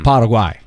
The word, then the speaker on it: Paraguay: It just depends on how strict you want to be with the Spanish pronunciation. Mm. Paraguay.